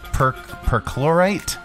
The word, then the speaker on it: perchlorate: How to pronounce percolate? – Perchlorate. – Oh, it's our pronouncify lady here. – Perchlorate. – Okay, perchlorate. – Now it makes sense. Perchlorate. – I'm learning. – Perchlorate. – Yeah. It's perchlorate.